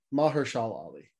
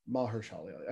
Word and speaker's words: Mahershala Ali: Marshall Ali. I'm pretty sure that's his name, but I'm probably screwing it up at this point. Mahershala Ali. – Ah. – Mahershala Ali. I knew I was saying it wrong.